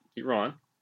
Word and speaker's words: Iran: I have been saying it wrong as well. I will work on that. Iran.